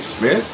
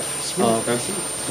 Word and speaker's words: Smear: You know, DJ Smith, DJ what? – How did you spell it? – So, S-M-E-A-R. Smith.